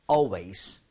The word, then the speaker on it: always: A-L 혹은 A-L-L 같은 스펠링은 발음할 때 올이 아니라 입모양 벌려줘야 된다는 거. Always remember that. 오케이? 자, 제가 두 번 드릴게요. Always.